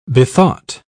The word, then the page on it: bethought: British English